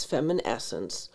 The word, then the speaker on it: Feminescence: Maca is the brand my friend recommended is called Feminescence, like feminine, but it's Feminescence. And maca is spelled M-A-C-A.